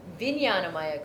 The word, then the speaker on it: Vijnanamaya: Now within this, we have, and I'm sure I'm saying this wrong, Vijnana Maya Kosha. I think that's how you say it. And it's spelled V-I-J-N-A-N-A-M-A-Y-A.